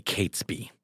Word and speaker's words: Catesby: who we pronounced as Catsby. Mm-hmm. It's apparently Catesby.